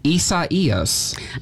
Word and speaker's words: Isaias: You're going to have to help me get used to saying that because it's spelled I-S-A-I-A-S. – That's right, but it's pronounced Isaias. That's the official pronunciation from the Hurricane Center, and they do the naming.